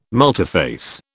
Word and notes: multiphase: British English

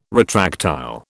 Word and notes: retractile: British English

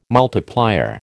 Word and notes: multiplier: American English

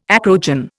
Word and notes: acrogen: Indian

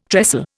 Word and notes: trestle: Indian

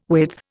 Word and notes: width: Indian

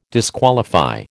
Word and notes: disqualify: American English

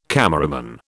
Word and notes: cameraman: British English

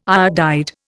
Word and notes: iodide: Indian

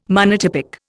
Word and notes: monotypic: Indian

